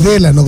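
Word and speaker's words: Délano: Es Delano, con acento en la. Es drújula con tilde. Toda palabra drújula lleva tilde.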